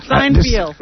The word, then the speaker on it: Seinfeld: It's Seinfeld. – Seinfeld.